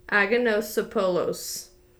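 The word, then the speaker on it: Anagnostopoulos: When she got into her teenage years, she met her future husband, a man named Babis Aganosopoulos. – Hey, that was good.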